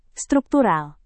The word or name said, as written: struktural